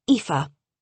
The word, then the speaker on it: Aoife: I've been better, and my name is Aoife, not Miss A. It's Irish and pronounced Aoife. Dr. Barland. My apologies. Aoife.